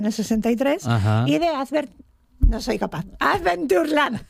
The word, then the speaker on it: Adventureland: Lo has dicho mal. Has dicho Adventure. Lo he dicho bien, porras. – Adventureland. – Dijiste Adventure. – Adventure.